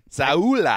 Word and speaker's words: Sowell: I kind of like him, but I just like the idea of He's an upgrade on Bradley Sowell, or Sowell, however you say his name, who was starting. – Saula. – I mean, Fletcher Cox and Brandon Graham are... – That's the correct pronunciation.